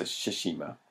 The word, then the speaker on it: Tsushima: There's no T, is there? – Yeah, there is at the start. – Yeah, but you don't say it, I don't think. – Oh, you don't say it. It's a silent T. – Yeah, it's a Tsushima.